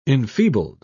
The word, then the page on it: enfeebled: British English